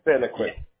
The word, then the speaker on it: Santaquin: the major city south is Santaquin. Sorry if I said that wrong. Santaquin? – Oh, you're good. Santaquin.